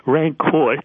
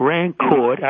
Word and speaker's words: Rancourt: Rancourt, silent T and silent S. So okay. D-E-N-I-S, one N, not Dennis, but Denis, D-E-N-I-S, mm-hmm. Rancourt, R-A-N-C-O-U-R-T, but you don't pronounce the T.